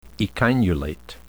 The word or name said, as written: echinulate